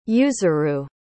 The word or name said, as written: yuzuru